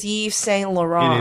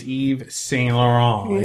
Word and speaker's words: Yves Saint Laurent: Now, Eve is in quotes, and it's spelled Y-V-E-S. You could have celebrated New Year's Eve in one of his trapeze dresses. – Who is Yves Saint Laurent? – It is Yves Saint Laurent.